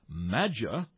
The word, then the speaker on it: matcha: Maja, on the other hand, and that's spelled M-A-T-C-H-A. It looks like matcha, but it's pronounced maja.